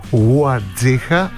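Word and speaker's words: Warzycha: Αυτός είναι προσπαθεί 7 χρόνια να μάθει και μας λέει ότι ο Βαζέχα στα πολωνικά η ακριβής προφορά του ονόματος είναι Ξιστόφ Ουαρτζίχα, αλλά το γου ελάχιστα ακούγεται.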